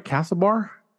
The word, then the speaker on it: Castlebar: Castlebar? – Mm-hmm.